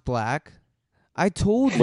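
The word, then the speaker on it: 6lack: Bro, he dropped merch that said it's pronounced black.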